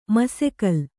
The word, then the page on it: ಮಸೆಕಲ್: masekal